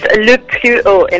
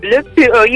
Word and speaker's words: Le Plus Haut: It's Le Plus Haut in French. – Le Plus Haut, right? – Le Plus Haut, you got it, you're close.